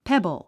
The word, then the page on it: pebble: pébl; ペボォ